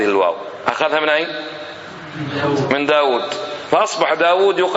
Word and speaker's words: داود: داود الله رازق واوين، واوين، لكن ما في أحد ينطق داود بواو واحدة، حتى لو ما يعرفه من قبل أول مرة يشوفه على طول يقول له داود داود.